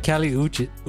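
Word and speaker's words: Kali Uchis: All right, so Kali Iuchi, is that how you say her name? – I don't know how you say that name. – Kali. – It's like Kali Uchi.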